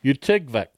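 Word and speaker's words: Utqiagvik: Utigvik.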